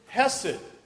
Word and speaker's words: hesed: But the word here, goodness, is hesed. Remember we talked about that last time? And to say it properly, it needs to kind of catch in the back of your throat when you're saying it.